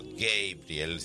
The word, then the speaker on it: Gabriel: Don't Give Up, No Te Rindas, tema de Peter Gabriel, o Gabriel, si les gusta la pronunciación original,